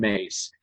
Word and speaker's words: Myss: And one of the first people I learned from was a woman named Carolyn Mace. And her last name is spelled M-Y-S-S.